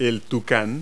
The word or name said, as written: toucan